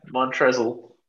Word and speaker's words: Montrezl: I love how the L in his name is silent. It's just like there. – Montrezl. – That I used to pronounce it like that for so long until I found out that L was silent.